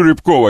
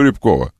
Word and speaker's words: Рябков: Вот, Сереж, ну я сейчас, вот по-хорошему сейчас, я люблю Рябкова. Если бы кто знал, как я люблю Рябкова. Рябков. Или Рябков, как правильно ударение? Рябков мне тоже нравится. – Рябков, мне Рябков кажется, все-таки лучше, да?